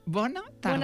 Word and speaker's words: bona tarda: Buena tarda. – Buena tarda. – Buena tarda. – Bona. – Bona tarda. – Bona tarda. Bona tarda, Barcelona.